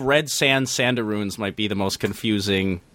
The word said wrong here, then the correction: Rabaroos: Oh, you just mispronounced it. Ugh. It is the Red Sand Rabaroos. – Oh, no. – They are rabbit and kangaroos.